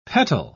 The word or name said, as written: petal